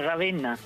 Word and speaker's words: Ravenna: Eh, lo he dicho bien, le he añadido una O, ¿no? ¿Es Ravenna? ¿Cómo es esto? Sí, sí, sí, has dicho bien, Ravenna.